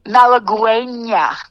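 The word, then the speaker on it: Malagueña: Malaguena.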